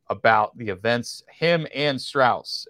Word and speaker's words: Strauss: I go back to it's the, southern pronunciation. Straw. – Southern pronunciation. Strauss Yeah.